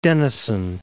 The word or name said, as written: Denison